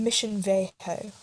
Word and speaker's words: Mission Viejo: In Mission Viejo. I feel like that's probably how you say it. – You did good there.